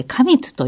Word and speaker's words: 花蜜: はい、お花まで飛んでいって、で、はい、で、そこで、花蜜、花の蜜と書いて花蜜というものを、まず体の中に蓄えるんですね。